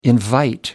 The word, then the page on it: invite: American English